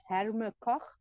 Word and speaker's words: Herma Koch: It's Herma Koch. I'll just spell that. It's H-E-R-M-A-K-O-C-H.